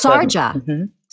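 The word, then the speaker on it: Sharjah: How do you pronounce this? Shira? – Oh, yes, the Sarja. Yeah, it's Sarja. Sarja? Mm-hmm. Sarja Performing Arts Academy in Dubai and on the Artistic Advisory Board for North Texas Performing Arts.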